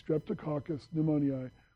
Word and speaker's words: Streptococcus pneumoniae: Streptococcus pneumoniae, repeat.